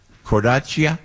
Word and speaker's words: Cornacchia: Cornacchia. – Cornacchia. Cornacchia. Oh, yeah. But see, we always do that. They Americanize it somehow. But I'm wondering in Italian, Cornacchia.